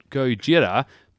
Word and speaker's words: Gojira: Normally, apparently it's called Gojira, but if you say it like it's like a Japanese action movie, it's like, oh, Gojira, Gojira. – I told you not to use it. – Oh, no, I think this is like a standard way of saying Gojira.